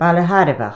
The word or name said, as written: BAILE THARBHACH